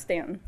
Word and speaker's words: Staunton: town called Stanton, spelled Staunton, but pronounced Stanton.